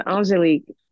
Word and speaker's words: Angélique: My first name is Angelique, actually. Angelique.